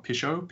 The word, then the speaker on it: Pichot: And the only person that is contesting him for the position of chairman is ex-Argentinian scrum half, Gus Pichot. Oh, Pichot. I'll say the silent T because it sounds more sophisticated.